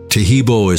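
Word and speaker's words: Taheebo: Tajibo is spelled T-A-H-E-E-B, like boy, O, then the word tea, and then the word club.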